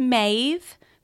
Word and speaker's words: Maeve: I really liked Maeve for her. It's a one syllable name, actually.